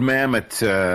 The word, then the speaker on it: Mamet: It was made in the year 2000, and it was written, directed, I guess, by David Mamet. Is that how you say his name? – Yes, David Mamet, playwright.